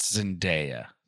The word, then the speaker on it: Zendaya: I do think it's pronounced Zendaya. – Zendaya, I'm sorry. – I want to get out ahead of that, you know. – Zendaya. – I think the Z is soft.